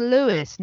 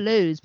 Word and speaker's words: Lewes: And it took me a very long time before I finally fell in with the fact they were saying Lewis, not lose. But of course, lose is spelt lose. L-E-W-E-S.